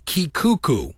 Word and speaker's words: Kekuku: There was a traveling Hawaiian quintet that would tour through the U.S., mainly the South, and they had a guitar player named Kikuku. Spell that K-E-K-U-K-U.